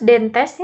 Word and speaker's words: dentes: Terus dentes, ini dentes ya dibacanya. – Dentis, dentes ya. Kan bahasa Latin.